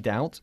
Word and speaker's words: doubt: I doubt it. Y doubt, por supuesto, se pronuncia con una B inexistente. – Mm-hmm. – Totalmente muda, ¿ok? Doubt.